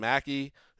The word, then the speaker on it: Maki: Allison Mackey, is that how you pronounce it? – Yes, M-A-K-I.